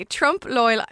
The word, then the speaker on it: Trompe-L'Oeil: There are Francophones out there cringing at me at the moment. But anyway, Trompe-L'Oeil is what I'm going to stick with, right? So that's how it's spelled and that's how it's pronounced.